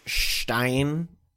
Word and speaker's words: Stein: He's like, "Is it offensive to pronounce Stein in a like that?" – No, it's not. That's how you pronounce it.